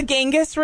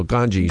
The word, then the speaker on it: Ganges: Yeah, there's the Ganges River. – No, Ganges.